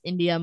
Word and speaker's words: Endya: but just like my name on Instagram is Endya Moler.